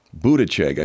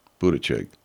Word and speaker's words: Buttigieg: Buttigieg. I finally know how to pronounce that cat's name. Buttigieg.